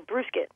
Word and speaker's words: brewskit: It's a beer grain dog bone, so it's kind of like a brew, like a beer, B-R-E-W, and then like a biscuit, S-C-U-I-T. So brewskit is actually how you pronounce it.